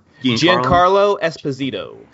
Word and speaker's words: Giancarlo Esposito: Giancarlo Esposito.